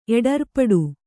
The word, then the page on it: ಎಡರ್ಪಡು: kannada